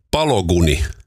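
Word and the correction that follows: Balogun: Folarin Pologuni. No niin, amerikkalainen. Folarin Pologuni. – Folarin Balagunin.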